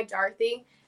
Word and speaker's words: Darthy: I learned that the traditional pronunciation, since it's an English name, it's actually pronounced like D-A-R-T-H-Y, Dorothy.